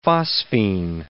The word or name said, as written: phosphine